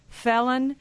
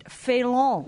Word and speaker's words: felon: Do you remember the first time I pronounced the word felon on the air? I pronounced it Félon. – Right. Like it was a French word.